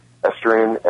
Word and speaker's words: Estuarine: Tell me what the director of the Estuarine, how do you say it? – Estuarine and Marine Fisheries Division.